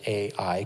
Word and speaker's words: Ai: We pronounce it Ai because it's spelled Ai. Some people just say that the pronunciation is I. So, however you want to pronounce it.